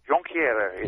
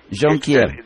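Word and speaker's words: Jonquière: Jonquiere is the right way. Jonquiere. – The French way. Yes.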